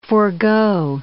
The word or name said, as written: forgo